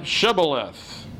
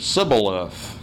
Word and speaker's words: Shibboleth: And if he said no, then they would say to him, say now, Shibboleth. But he said, Sibboleth, for he could not pronounce it correctly.